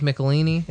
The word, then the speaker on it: Michelinie: but by Todd McFarlane and Dave Michelinie.